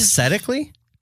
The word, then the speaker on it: aesthetically: It's aesthetically pleasing, which I think is... – Aesthetically? – Aesthetically. – Okay. – I might not have pronounced the T the first time.